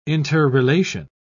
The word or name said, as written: interrelation